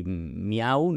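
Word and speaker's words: Meow: Se llama eh, Jordi Miau. ¿no? Es como se diría. Realmente no sé cuál es su apellido, pero es, lo que él tiene mm, como nombre, ¿no? En realidad se escribe Meu sería como decir miau en inglés o en, otros idiomas.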